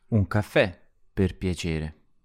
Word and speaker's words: piacere: In piacere, the first part is spelt P-I-A, but the I sounds like a Y. So it's pia, not pia.